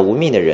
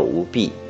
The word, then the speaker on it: 吴宓: 国学大师陈寅恪是大家所熟悉的，知道吴宓的人或许比较少。吴宓当年也叫吴弼，因为普通话的读音和当时的乡音不同，所以有了这样两个版本。那我们今天统一读成吴宓。